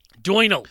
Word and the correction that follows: Doinel: we called him. You Doinal. Said Doinal last week, last episode, and in my head I was like, ah, fuck, that's so not. – Yeah, yeah, I thought pronounced. That. It was in my head as well. I thought that. – A French guy named Doinal. That can't be right. – Doinel. Antoine Doinel.